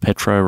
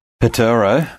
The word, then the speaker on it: Petero: Petero, I think is how you pronounce it phonetically, his first name.